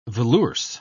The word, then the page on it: velours: British English